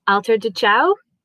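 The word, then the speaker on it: Alter do Chão: And we really want to hear about your work with, now please correct me if I'm pronouncing this wrong, Alter de Chao, is that right? – Yes, kind of, yes.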